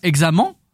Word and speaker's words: examen: Ça s'écrit E-N à la fin, donc on devrait lire examen. Et en fait, ça se prononce main, comme si c'était une main, M-A-I-N.